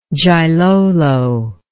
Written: Gilolo

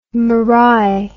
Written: marae

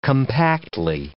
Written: compactly

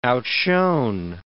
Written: outshone

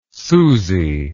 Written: suzie

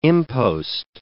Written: impost

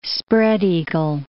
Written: spread-eagle

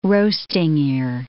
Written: roasting ear

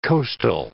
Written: coastal

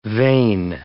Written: vein

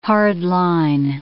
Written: hard-line

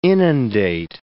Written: inundate

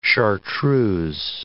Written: chartreuse

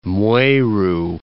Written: Mweru

